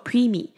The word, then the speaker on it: preemie: 那接下来我们要看到今天的第二个单字是 preemie，它的 ee 跟 ie 都是发长音的 e，念作 preemie。